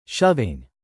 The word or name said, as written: shoving